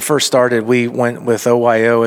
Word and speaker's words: OYO: how do you pronounce it? Is it just OYO? – OYO. – Okay. Yeah, yeah. I didn't know if it was OYO or.